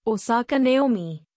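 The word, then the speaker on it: Osaka Naomi (大坂なおみ): Naomi Osaka, Osaka Naomi, Japanese pronunciation, Osaka Naomi, born October 16, 1997, is a Japanese professional tennis player.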